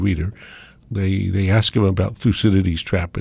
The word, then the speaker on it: Thucydides: Did they know how to pronounce Thucydides or? – No, Thucydides. – I knew I was going to get it wrong again. – No, no, no. Thucydides. Yes, indeed.